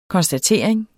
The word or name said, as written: KONSTATERING